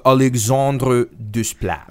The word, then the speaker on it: Alexandre Desplat: Justine, excusez-moi, c'est Alexandre Desplat. – Okay. – Oui, c'est Alexandre Say it right.